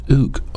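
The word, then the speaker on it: ouk (οὐκ): The word is pronounced ouk, O-U-K, almost like oink, like a pig, but ouk.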